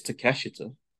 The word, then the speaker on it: Takeshita: This might be the only time this will ever happen, but I think I can correct you on a Japanese pronunciation. It's Takeshita.